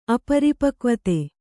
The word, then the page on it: ಅಪರಿಪಕ್ವತೆ: aparipakvate